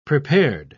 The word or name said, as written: prepared